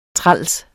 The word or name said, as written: træls